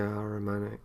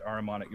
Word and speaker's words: Ahrimanic: All of that you're describing, that all feels very, how do you pronounce it? What's the Persian, the Ahrimanic, the satanic figure? – Yeah. Ahrimanic. Yeah. – Is it Ahrimanic? Right. That's all very Ahrimanic.